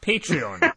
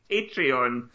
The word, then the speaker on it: Patreon: Patreon. – Patreon.